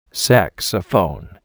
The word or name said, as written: saxophone